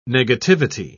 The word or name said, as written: negativity